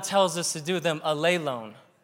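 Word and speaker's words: alelone (ἀλλήλων): See, that second accent is where you put the accent. Greek's cool because you know where to put the emphasis. You put the emphasis on the right syllable. And in this case, it's on the eta, so alelone. That's how you say that.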